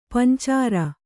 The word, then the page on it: ಪಂಚಾರ: pancāra